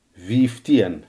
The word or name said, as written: vijftien